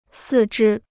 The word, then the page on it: 四肢: sì zhī